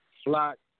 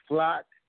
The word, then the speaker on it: Flock: For those who don't know how to say it, the name is Flock, like a flock of birds. And Gaza, like the Gaza Strip.